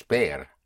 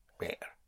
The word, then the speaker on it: ver: The word for to see is ver, spelled V-E-R, with vision, ver, to see. The V in Spanish has the same sound as the B. V and B have the same sound. In other words, you pronounce the V with your lips, and sometimes it's a soft B, so it may sound like a V, but pronounced with your lips. So to see is bear, yes, like seeing the bear.